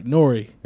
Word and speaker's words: N.O.R.E.: I'm in on N-O-R-E. – No, man. Say it right, Norrie.